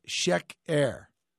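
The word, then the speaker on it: sheker (שֵׁכָר): but it's pronounced sheker, sheker.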